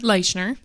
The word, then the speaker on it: Leischer: Leischer.